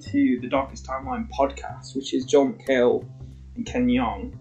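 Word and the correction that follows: Jeong: Or is it Jung? I think it's saying Jung on the podcast, which I've been saying his name wrong the whole time.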